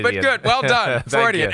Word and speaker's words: Freudian: After 2.5 years of doing this show, you finally nailed it with Freudian. – Thank you. – I have heard every possible pronunciation. – Yes. – None of which were even remotely accurate. Freudian, extra syllables.